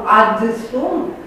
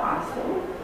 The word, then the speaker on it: adsum: Chiar așa făceau latinii, pronunțau adsum, probabil pronunțau asum, și notau, în măsura în care acceptau norma gramaticală, adsum.